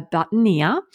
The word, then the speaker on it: boutonniere: So traditionally referred to as a boutonniere, a French word pronounced boutonniere. And this design consists of two or perhaps just one bloom with accenting greenery and flowering fillers.